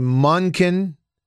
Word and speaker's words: Monken: I don't know because I see it in my head as M O N K E N, and I want to say Munkin, but that didn't roll off the tongue. So you say Munkin, which is like monkey.